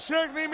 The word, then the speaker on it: Szczęsny: tiene lo que de Szczęsny, mira el reloj, ya, ha comenzado el partido.